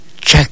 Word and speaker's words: chac: Rojo, escuche usted, rojo se pronuncia de esta manera. Chac. Escúchelo usted bien. Chac. Así como suena.